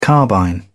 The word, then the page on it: carbine: British English